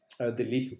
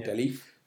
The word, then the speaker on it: Delhi: You've got all these, like, basically you've got to, the first city you grow, so Delhi or Delhi, depends how you want to pronounce it, so that's your capital.